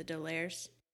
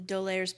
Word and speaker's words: D'Aulaire: But like the classic one that always springs to mind is the Dolaires, which is the Dolaires Book of Greek Myths by Ingrid Dolaire, which is spelled D'Aulaire.